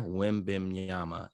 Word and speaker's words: Wembanyama: Victor Wimbimyama is sensational.